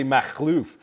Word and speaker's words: Makhlouf: That's the American pronunciation of my last name. I'm just trying not to scare white people, man. It's actually Makhlouf, if you want to get really phlegmy with it.